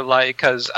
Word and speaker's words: Laika: Is that how you say it, Laika? – The Bumblebee spinoff movie? – Yep. – The L-A-I-K-A. – Yep, Laika.